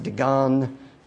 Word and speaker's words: Dagon: So sometimes Dagon is D-A-G-O-N, sometimes it's D-A-G-A-N, sometimes it's pronounced Dagon, Dagon.